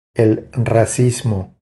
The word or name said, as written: El racismo